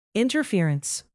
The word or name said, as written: interference